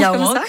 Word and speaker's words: Yaouank: Ça se prononce Yaouank, comme ça ouais, ouais.